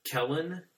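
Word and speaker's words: Kelen: It's Keelan. It's spelled like Helen, but with a K.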